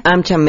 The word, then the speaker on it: AmCham: AmCham México, México.